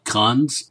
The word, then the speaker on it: Khund: Actually, the aliens are called Khunds. Is there's no apostrophe, so it's not two syllables. It's just Khund.